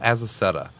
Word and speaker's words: Azaceta: It's a name that I constantly mispronounced, and he said it's Spanish. But it's Paul Azaceta.